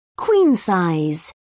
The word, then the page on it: queen-size: British English